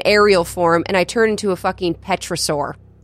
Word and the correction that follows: pterosaur: It's a pterosaur, I think. – Pterosaur. – They call it a petrosaur in the animal, but is there a letter between the P and the T? – Oh, no. – That's my question. – No, it's just a P and then a T.